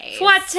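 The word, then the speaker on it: fouettés: And then, yeah, like those final, how do you say it, fouettes? – Fouettes. – Yeah. It's spelled like it would be fouette.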